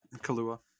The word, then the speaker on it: Kaluuya: Daniel Kaluuya? – Kalua.